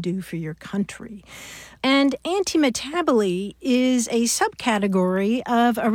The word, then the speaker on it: antimetabole: It's spelled A-N-T-I-M-E-T-A-B-O-L-E, anti-metabole.